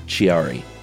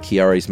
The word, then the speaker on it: Chiari: I think it's C-H-I-A-R-I. And I bet you it's not Chiari. – You got to say it like an Italian person.